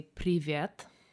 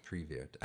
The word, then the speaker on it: Привет: To say hello to somebody, you say privet. – Privet. – Privet. – Привет. I can't. – You can't roll your R's? – I can't roll.